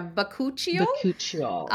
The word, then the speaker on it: Bakuchiol: And I don't know if I'm going to say it right, but it's the Bakuchiol. Did I say it right? Yeah, I'll put it up on the screen. Yeah, Bakuchiol.